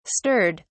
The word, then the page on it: stirred: British English